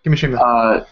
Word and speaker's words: Kimishima: which was from Nintendo President Tatsumi Kimishima, if that's how you say it. – Yes, Kimishima.